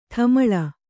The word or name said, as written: ಡಮಾಲು